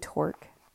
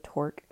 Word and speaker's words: Tork: I'll tell you the exact thing, but it was all referencing this study that was done by this guy named Tork, spelled like stork without the s on the front, so Tork, and it was saying, and I, this takes me back to the Pomodoro.